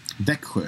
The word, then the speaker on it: Växjö: Växjö.